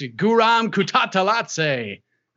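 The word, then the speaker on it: Kutateladze: if I pronounce Kutatzalatze, I have to pronounce it like a ring announcer, because that's how I heard it and got the pronunciation. So you can't just say it normally. It's Guram Kutatzalatze.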